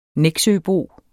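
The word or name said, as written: NEXØBO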